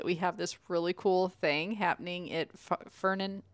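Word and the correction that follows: Ferran Park: I can never say it right. – Ferdinand Park? – No. – Farron Park. – Farron. Whatever. Farron Park.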